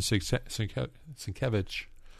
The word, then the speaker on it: Sienkiewicz: Since you mentioned Sienkiewicz, I did say that right. – I think you did.